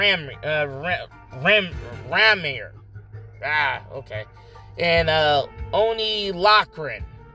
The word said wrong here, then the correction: Lorcan: or Lorcan. Oney Lorcan.